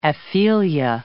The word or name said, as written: aphelia